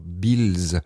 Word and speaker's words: Beales: Tout d'abord, Trevor Beals, je vais le prononcer comme ça s'écrit B-E-A-L-E-S.